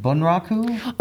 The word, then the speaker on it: Bunraku: is it Bunraku? – Oh, yeah. Yes. You said it exactly right.